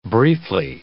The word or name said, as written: briefly